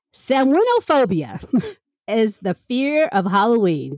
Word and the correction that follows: Samhainophobia: Samhain. Excuse me. I'm saying it wrong too. I'm telling people how to say it and I'm wrong too. Samhain. – Samhainophobia.